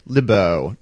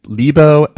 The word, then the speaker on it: Lebow: Fred LeBeau. Now, we're going to probably say his name Lebo and LeBeau both ways. I've heard it literally dozens of times in both ways.